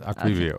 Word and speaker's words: Aquivio: All right, so am I saying it right? Aquavio. – Yes, Aquavio. Okay.